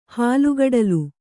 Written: ಹಾಲ್ಗಡಲು

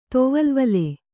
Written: ತೊವಲ್ವಲೆ

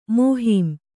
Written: ಮೋಹೀಮ್

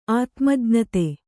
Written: ಆತ್ಮಜ್ಞತೆ

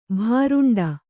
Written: ಭೂರುಂಡಿ

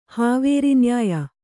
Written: ಹಾವೇರಿನ್ಯಾಯ

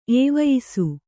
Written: ಏವಯಿಸು

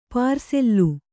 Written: ಪಾರ್ಸಲ್ಲು

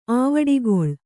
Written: ಆವಡಿಗೊಳ್

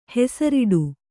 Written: ಹೆಸರಿಡು

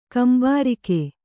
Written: ಕುಂಬಾರಿಕೆ

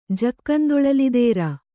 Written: ಜಕ್ಕಂದೊಳಲಿದೇರ

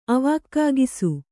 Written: ಅವಾಕ್ಕಾಗಿಸು

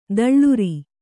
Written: ತಳಿರು

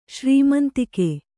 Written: ಶ್ರೀಮಂತಿಕೆ

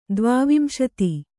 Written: ದ್ವಾವಿಂಶತಿ

